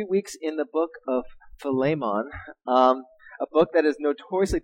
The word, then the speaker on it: Philemon: So if you're ever wondering how to pronounce this, technically in the Greek, it's Philemon, right? But don't worry, I'm going to probably say Philemon or Philemon or whatever during this whole time. But don't worry if you can't get this correctly, all right? But Philemon is technically the correct pronunciation.